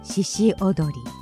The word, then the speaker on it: 鹿踊り: 鹿踊りと書いて獅子踊り、賢治のふるさとのとても有名な踊りだそうですが、今回作品の中で鹿の言葉がかなりなまっているのがとっても難しかったです。